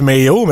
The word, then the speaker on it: Mayo: Ça se prononce Mayo, mais M-A-Y-O ouais.